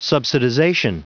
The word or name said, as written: subsidization